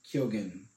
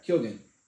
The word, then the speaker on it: Keoghan: Keoghan. – Yeah. – Is how he pronounces it. – Yeah. – Keoghan.